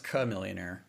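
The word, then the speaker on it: Chamillionaire: Cha Millionaire. – You don't remember this? – It's pronounced Ka Millionaire. Like the lizard.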